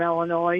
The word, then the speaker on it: Illinois: And then the Illinois thing, if you're not from Illinois, you'll add the S just like you'll try and add it to Iroquois.